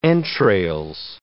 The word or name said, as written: entrails